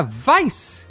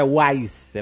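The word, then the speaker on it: Weisz: O Vice se pronuncia Vice. Não é Wise, é Vice. Ah, então eu tô falando certo. Você tá. Olha aí. Se fala como a palavra vício em inglês.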